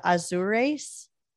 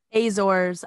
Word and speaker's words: Azores: I said that wrong. – Azores. I just learned how to say it like literally 2 days ago. – It's not Azores. – I thought it was Azores, no, which come on. Sounds cooler, but I guess it's Azores.